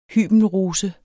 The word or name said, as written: hybenrose